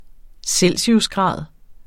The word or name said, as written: celsiusgrad